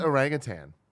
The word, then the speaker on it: orangutan: How do you say it? – Orangutan? – You had a G at the end? – Orangutan? Is it just orangutan? – It's just orangutan.